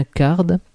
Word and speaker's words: card: Le card-driven, on prononce bien card, comme une carte à jouer en anglais, et non pas carte, comme les petites voitures de course chères aux plombiers italo-japonais.